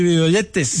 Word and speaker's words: videoyentes: De las asas, salude a los y pronuncie bien, por favor, YouTube videoyentes. – Ah, yo digo los videntes. – No. A ver, YouTube videoyentes. ¿Cómo se dice? – YouTube videoyentes.